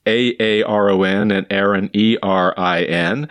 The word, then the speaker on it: Erin: To me, they both have a short E sound as the vowel. And if you reverse Erin, the boy's name, Erin, you get Nora, N-O-R-A.